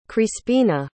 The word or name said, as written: crispina